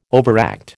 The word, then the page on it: overact: American English